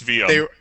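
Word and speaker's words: Sveum: Pronounce it right. It's Svame.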